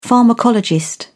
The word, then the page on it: pharmacologist: British English